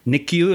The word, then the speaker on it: NICU: Topics today include uh, what it what it's like to work, particularly in the in the NICU.